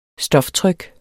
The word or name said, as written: stoftryk